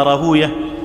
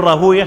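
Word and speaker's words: راهويه: وقال حاشد ابن إسماعيل رأيت إسحاق بن راهوية جالسا على المنبر، وطبعا احنا بنقول راهوية ما بنقولش راهوي، بنقول سيبوية مش سيبوي، لأن هناك نطقان صحيحان، المحدثون ينطقون كل اسم ختم بوي كما أنطقوا هكذا أوية، لأنهم يرون حديثا ضعيفا أن ويه اسم شيطان فبيشيلوا دماغهم، يعني الحديث ضعيف بإجماع المحدثين أن ويه اسم شيطان، ولهذا هم يفرون فيقولون سيبوية حمدوية نفطوية وهكذا.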